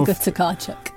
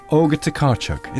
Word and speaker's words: Olga Tokarczuk: Olga Tokarczuk. – Olga Tokarczuk.